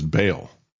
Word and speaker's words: Baal: And yet, if you look at the cult of Baal that is there, again, just as a background, always would pronounce it as Baal because it's B-A-A-L.